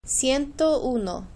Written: ciento uno

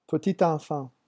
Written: petit enfant

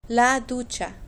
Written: la ducha

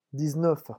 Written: dix neuf

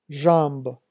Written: jambes

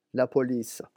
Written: la police

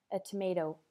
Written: a tomato

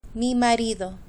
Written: mi marido

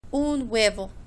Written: un huevo

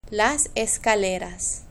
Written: las escaleras

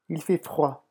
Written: il fait froid

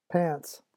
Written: pants

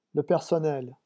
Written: le personnel

